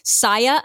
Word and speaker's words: Saya: Is it pronounced Saya? – Yes, it is. – Okay, so let me repeat it again. Saya Unveiled, South Asian Mental Health Spotlighted.